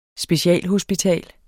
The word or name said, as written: specialhospital